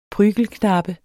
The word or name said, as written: prygelknabe